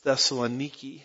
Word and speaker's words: Thessaloniki: I've actually been to Thessalonica myself. They pronounce it as Thessaloniki. It's always disappointing learning you've been mispronouncing a word your whole life, but an old dog, new tricks, so I'm just going back to Thessalonica.